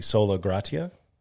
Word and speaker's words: sola gratia: But the 5th point is always the sola gratia.